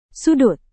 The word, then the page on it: sudut: Indonesian